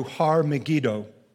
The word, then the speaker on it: Har Megiddo: Now, Armageddon is pronounced in the Hebrew Har Megiddo.